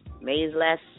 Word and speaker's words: Maysles: So I definitely advise you guys to come on out this Saturday, May 30th, to the, I'm saying it wrong, I know it, to the Maze-less. Something like that. Theater in Harlem. It's spelled M-A-Y-S-L-E-S. Okay.